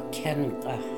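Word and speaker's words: Cin k'ikh: You have to practice your Kinka.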